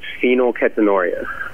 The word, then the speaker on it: phenylketonuria: And it's actually pronounced phenylketonuria.